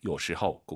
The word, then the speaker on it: 有时候: 有时호 아니죠. 오유는 어우 발음이에요. 허우예요. 有时候 꼭 기억하세요.